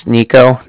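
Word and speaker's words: Niko: Again, my name's Nico. That's N-I-K-O.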